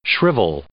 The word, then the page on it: shrivel: verb